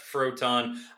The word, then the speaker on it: Froton: Just two Fs, CF Froton.